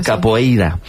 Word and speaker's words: capoeira: Pues todos sabemos lo que es la copeira, ¿verdad? Capoeira. Qué mal pronuncio últimamente.